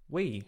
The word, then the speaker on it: Wii: the statement came out saying, the console's called Wii. It's pronounced Wii, double E.